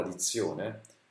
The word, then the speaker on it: dizione: Quindi l'accento cade sulla zio, cade sulla sillaba zio, di zio ne.